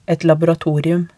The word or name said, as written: et laboratorium